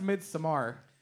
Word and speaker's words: Midsommar: It's pronounced Midsommar.